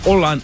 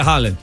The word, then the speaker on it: Haaland: Todavía no lo estoy diciendo bien, pero se dice, en vez de decir la doble A se convierte como en O. – Oh, wow. – Holland or Holland, algo así. – Oh, wow. – Pero the point is que no se dice Haaland.